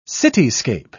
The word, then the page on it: cityscape: British English